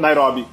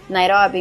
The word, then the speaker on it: Nairobi: eu não sei como é que fala em português. – Nairobi. – Nairobi. – Nairobi em português.